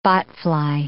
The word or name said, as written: botfly